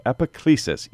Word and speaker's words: Epiclesis: He is the pastor of Epiclesis, E-P-I-C-L-E-S-I-S. I think I'm saying it right. – Epiclesis. Yeah, no, you got it.